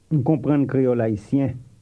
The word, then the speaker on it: Haïtien: The word for Haitian is Haïtien. – It has a nasal sound to it.